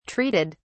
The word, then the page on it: treated: British English